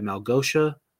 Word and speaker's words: Malgosha: He is imprisoned by Malgosha.